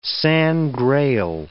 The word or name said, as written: Sangreal